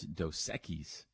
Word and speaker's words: Dos Equis: I was like, it's Dos Equis, and he goes, yeah, I know. Like he knows he's not saying it right.